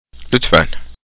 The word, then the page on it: lütfen: Turkish